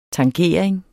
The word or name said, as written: tangering